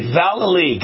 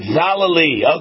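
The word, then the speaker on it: Vallely: Valley, but if you sing it, Wayne, you'll get it right. Valley. – All right, Valley, got it. Valley, okay.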